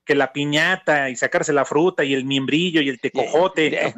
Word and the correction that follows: membrillo: De Tecojote y membrillo, idiota.